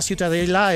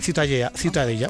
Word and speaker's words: Ciutadella: ¿cómo se pronuncia? – La Ciutadella. – Ciutadella. Bueno, pues Ciudadela también en castellano. – Ciudadela, sí. – Pues eso, ha quedado clarísimo. Pero, ¿para qué se meten? – ¿Tú cómo lo pronuncias? – Ciutadella. – Muy bien.